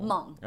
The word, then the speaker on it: Hmong: Hmong. – Hmong. – Yes. – I always know how to say that. Hmong. – Hmong. – Okay. – It's just silent H.